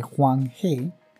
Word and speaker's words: Huang He: En 2005, científicos de la Universidad de Pekín encontraron cerca del río Amarillo, que se pronuncia realmente Huang He,